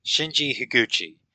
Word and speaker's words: Shinji Higuchi: Shinji Higuchi.